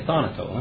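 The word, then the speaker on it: Thanato (θανάτω): Okay, thanato.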